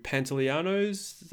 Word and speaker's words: Pantoliano: And the dynamic between them and sort of Joe Pantoliano's, that's how you pronounce his name, the, you know, captain and stuff.